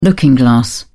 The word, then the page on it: looking glass: British English